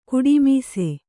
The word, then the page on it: ಕುಡಿಮೀಸೆ: kuḍimīse